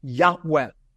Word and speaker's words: Yahweh: Most Semitic scholars believe that's pronounced a breathy Yahweh.